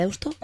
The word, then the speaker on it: Deusto: Deusto.